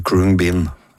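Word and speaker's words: Khruangbin: Jeg tror du sa navnet da, men du sa vel Kruang Bin, som sånn det, holdt på å si, ser ut som det kan tenkes å skulle uttales, det I hvert fall sånn det står. Men etter litt research så tror jeg vi mer I retning at det heter Kruang Bin.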